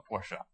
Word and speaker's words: Porsche: Okay, and that is the correct way to say it, Porsche.